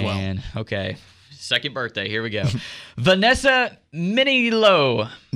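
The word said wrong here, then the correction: Minnillo: How do you say it? – Manilo.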